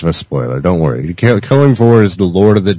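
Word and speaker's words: Kelimvor: And I didn't say it right, did I? – You said it right. Yeah, I believe you said Kelimvjor.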